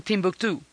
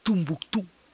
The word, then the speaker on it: Timbuktu: Entonces, se pronuncia de muchas maneras según quien lo esté pronunciando. Para, para muchos es, eh, para los ingleses y los árabes lo llaman Timbuktu. Los franceses lo llaman Tumbuktu. Tinabactu es como le dicen los tuaregs.